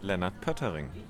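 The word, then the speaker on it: Lennart Poettering: Leonard Pottering.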